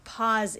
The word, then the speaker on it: Pause: So another way we could discuss pauses is when, you know, a pause in German in an opera, when you go to an opera or performance, intermission is called Pause, which is pause, P-A-U-S-E, just pronounced Pause.